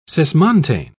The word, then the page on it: cismontane: British English